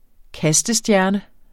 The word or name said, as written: kastestjerne